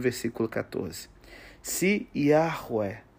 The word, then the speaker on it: Yahweh: Então, assim, o primeiro H é aspirado, Yahweh.